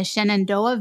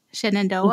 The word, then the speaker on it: Shenandoah: She lives in the Shenandoah Valley. Did I say that correctly? – You did. – Shenandoah, mm-hmm.